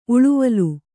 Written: ಉಳುವಲು

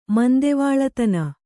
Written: ಮಂದೆವಾಳತನ

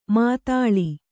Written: ಮುತ್ತಲು

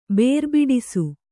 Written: ಬೇರ್ಬಿಡಿಸು

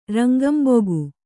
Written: ರಂಗಂಬೊಗು

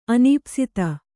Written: ಅನೀಪ್ಸಿತ

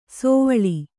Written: ಸೋವಳಿ

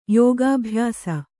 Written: ಯೋಗಾಭ್ಯಾಸ

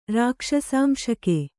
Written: ರಾಕ್ಷಸಾಂಶಕೆ

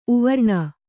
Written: ಉವರ್ಣ

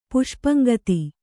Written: ಪುಷ್ಪಂಗತಿ

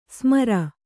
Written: ಸಮರು

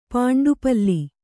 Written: ಪಾಂಡುಪಲ್ಲಿ

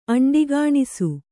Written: ಅಂಡಿಗಾಣಿಸು

